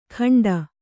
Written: ಖಂಡ

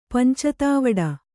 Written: ಪಂಚತಾವಡ

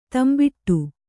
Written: ದಂಬಡಿ

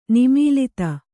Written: ನಿಮೀಲಿತ

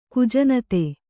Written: ಕುಜನತೆ

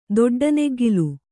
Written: ದೊಡ್ಡನೆಗ್ಗಿಲು

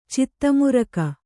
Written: ಚಿತ್ತಮುರಕ

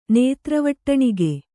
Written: ನೇತ್ರವಟ್ಟಣಿಗೆ

